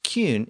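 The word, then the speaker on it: cyn: Another common Old English suffix was kin, pronounced kyun in Old English.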